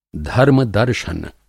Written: धर्म-दर्शन

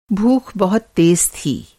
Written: भूख

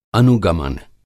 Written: अनुगमन

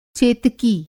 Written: चेतकी

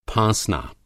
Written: फाँसना

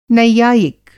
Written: नैयायिक

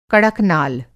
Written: कड़कनाल

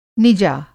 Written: निजा

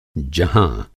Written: जह

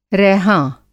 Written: रैहाँ

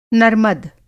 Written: नर्मद

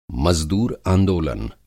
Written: मज़दूर-आंदोलन

